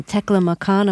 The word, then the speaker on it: Taklamakan: the most remote part was in Taklamakan. Am I pronouncing that correctly? – You're pronouncing it properly.